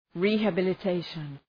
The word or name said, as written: rehabilitation